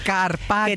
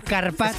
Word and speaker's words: carpaccio: Carpaccio. – Que te has perdido una letra. Carpaccio.